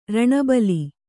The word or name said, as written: ರಣಬಲಿ